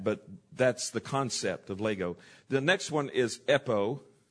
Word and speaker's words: lego: It's almost like Legos, but it's Lego. It's L-E-G. It's a short E and long O, Lego.